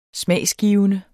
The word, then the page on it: smagsgivende: [ -ˌgiːvənə ]; [ -ˌgiˀvənə ]